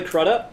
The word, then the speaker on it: Crudup: Billy Crudup? – Crudup.